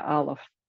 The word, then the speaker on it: Aleph: They got handed slates to, you know, and they were going to learn the first letter of the Hebrew alphabet, which to my understanding is Aleph. – Yeah, Aleph. – Aleph. – That's the way it's pronounced, yeah. – Aleph.